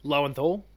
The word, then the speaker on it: Lowenthal: Lowenthal.